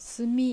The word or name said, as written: Sumi